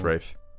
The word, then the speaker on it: Ralph: Yeah, it's pronounced Rafe. It's pronounced Rafe. He's right. R-A-F-E. – Ruben just got fucked. – Yeah, it's because it's spelled Ralph.